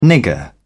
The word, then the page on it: nigger: British English